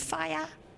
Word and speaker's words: Faya: Stanley, zijn eerste hoofdrol was in 1995 in Faya. Zeg ik dat goed? – Ja, klopt. – Dat zeg je heel goed.